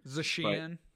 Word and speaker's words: Zacian: Additionally, I think you know, the obvious pick of Pokemon that are going to be good will be Zacian or Zacian. I don't know exactly the correct pronunciation. – Zacian.